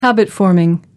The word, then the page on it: habit forming: American English